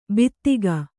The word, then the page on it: ಬೀದಿಗ: bīdiga